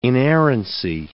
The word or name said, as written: inerrancy